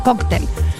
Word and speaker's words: cóctel: escriban cóctel en español, así como se los digo, C-O-C, con acento en la O, tel, cóctel.